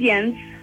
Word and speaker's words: Jens: It's spelled J-E-N-S, but it's pronounced Jens.